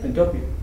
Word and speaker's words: Pantopia: How you pronounce that in English? Like utopia, but Pantopia? Pantopia. Yeah, Pantopia.